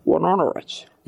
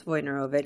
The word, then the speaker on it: Wojnarowicz: Wajnarowicz.